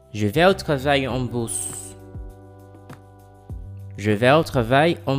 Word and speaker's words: bus: En bus. Listen and repeat. – En bus. En bus. – The spelling of the word bus is the same of the English word bus, but it has a different pronunciation.